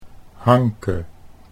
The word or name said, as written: Hanke